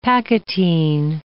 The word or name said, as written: pachytene